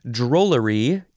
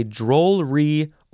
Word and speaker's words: drollery: Drollery is next. You can say drollery or drollery. Two syllables, three syllables.